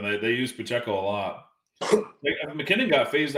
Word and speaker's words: Pacheco: Yeah, Isaiah Pacheco.